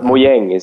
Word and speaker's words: Mojang: But the correct spelling is with an A, and the correct pronunciation, I think, is the English one, right? Which is Mojang. – That's what they say. – That's what it's intended anyway. So, But Mojang is the Swedish word.